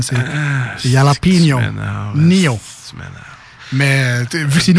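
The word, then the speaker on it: jalapeño: Écoute, les manchettes Jalapeno, c'est pas compliqué, les manchettes Jalapeno. C'est correct, ma prononciation? Là? – Non, mais c'est pas grave. – C'est Jalapeno.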